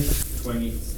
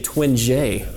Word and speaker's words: Twenge: Uh, number two, Josh, how do you pronounce that name? – Twenge. – Twenge, all right. I was saying Twin J before.